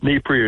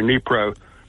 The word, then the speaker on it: Dnieper: Dnieper River. The D is not pronounced. It's Dnieper or Dnipro River, okay?